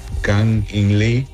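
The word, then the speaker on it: Kang-in Lee: Me gusta el jugador, se llama King. Espera, a ver si me recuerdas pronunciar su nombre correcto. Se llama Kang In Lee.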